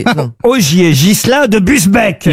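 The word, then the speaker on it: Busbecq: Ogier Gilin de Busbeck, pardon, j'ai écorché son nom. – Il me semblait bien aussi. – Ça, c'est chou. Ça, oui. Est-ce que vous pouvez appeler Busbeck? – Busbeck. Ogier Gislin de Busbeck. – Ah, Gislin.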